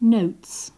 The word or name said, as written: notes